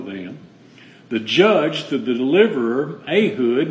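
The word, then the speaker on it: Ehud: Ahud, is how that's pronounced. I pronounced it wrong for a long time.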